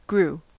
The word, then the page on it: grew: American English